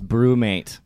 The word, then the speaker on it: BruMate: Are you saying Brewmate? – Brewmate. – Brewmate. – Brewmate. – Brewmate. – Brewmate. – Brewmate. – It's Brewmate.